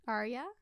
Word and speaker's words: Arya: Arya is pronounced Arya. I thought it was Arya, because I only ever read it. – You said it right. – I know. I thought it was Arya. It's Arya. – Those are the same. Say it again. – Arya? – Uh-huh. – Arya. Like, there's an emphasis on the Y.